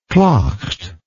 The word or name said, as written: claught